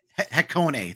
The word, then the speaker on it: Hakone: We've got Eagle Rock Speedway, Grand Oak Raceway, Kailami Grand Prix Circuit, Hakone. My pronunciation is terrible. – Hakone. – Thank you.